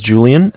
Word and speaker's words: Julian: All right, my name is Julian. It's spelled J-U-L-I-A-N.